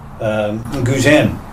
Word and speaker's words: Guzheng: to Jing's classical Guzheng.